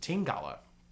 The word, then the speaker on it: Tingala: So tin like the metal, gala like the apple, right? And it's pronounced Tingala.